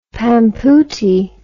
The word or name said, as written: pampootie